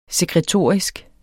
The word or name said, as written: sekretorisk